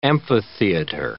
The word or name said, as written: amphitheater